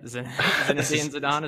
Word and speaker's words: Zinedine Zidane: Zinedine Zidane, is that how you pronounce it? – Yeah, that is accurate.